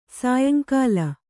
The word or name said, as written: ಸಾಯಂಕಾಲ